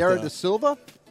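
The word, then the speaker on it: Vieira da Silva: Viera da Silva? – Sounds about right.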